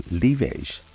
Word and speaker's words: livèche: Lavage, or livage as it is pronounced in French, is a perennial plant that is part of the Apiaceae family, or parsley family, which includes parsley, of course, dill, and carrot.